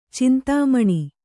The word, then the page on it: ಚಿಂತಾಮಣಿ: cintāmaṇi